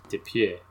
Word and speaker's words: Dupieux: And we keep just saying this filmmaker, only because I bothered to actually look up how to pronounce his name. That I definitely feel like I should actually try to pronounce it on the show. And I hope if I mangle it, my apologies. But Quentin Dupieux. – Dupieux. – It's French. He's a French filmmaker who built this.